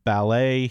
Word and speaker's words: ballet: So continuing, I guess, with the syllable stress thing, B-A-L-L-E-T is ballet here in the United States. I remember this very much from the movie Billy Elliot, that the British call this ballet. – Yes. – Ballet. – It's exactly like that. – moving on, B-U-F-F-E-T, that's a buffet here in the United States.